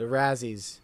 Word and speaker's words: Razzies: which was the Razzles. It's called the Razzles, right? I said that right? – The Razzies. – The Razzies.